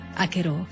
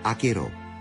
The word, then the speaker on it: Acherò: Ripete più volte la parola Acherò, sottolineando l'accento sull'ultima sillaba. Acherò, nel dialetto di Lourdes, significa quella cosa, diversamente da Achero, che significa quella donna.